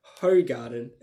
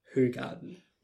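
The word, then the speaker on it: Hoegaarden: And it's not pronounced Hogarden. It's actually pronounced Hogarden.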